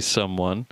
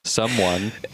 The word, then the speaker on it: Sumwn: Most people just call me someone. You can call me some. You can call me one. It's spelled S-U-M-W-N. Someone. – S-U-M-W-N? There's not another vowel in there? – S-U-M-W-N.